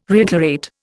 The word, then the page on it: reiterate: Indian